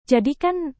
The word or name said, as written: jadikan